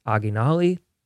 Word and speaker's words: Oginalii: It is spelled O-G-I-N-A-L-I-I. They are a local rock band here in Nashville, Tennessee. They're awesome. They were great at the show. And again, I believe it's pronounced Oggy Nolly.